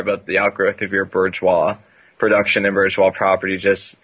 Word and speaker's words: bourgeois: To our intended abolition of bourgeoisie's property, the standard of your... – It's pronounced bourgeois.